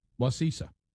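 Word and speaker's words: Wacissa: Now, Wasisa. Wasisa Christian Church. – You got a feeling that's not right. – No, I think it's right. Wasisa. That's what the locals say.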